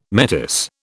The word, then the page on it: metis: British English